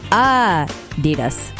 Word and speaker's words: Adidas: Yeah, apparently Americans pronounce Adidas wrong. It's supposed to be Adidas, with a slight emphasis on the first syllable, Adidas, Adidas. And apparently most Americans say Adidas, Adidas, Adidas.